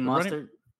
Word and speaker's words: Mostert: Raheem Mostert.